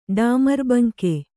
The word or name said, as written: ಡಾಮರ್ಬಂಕೆ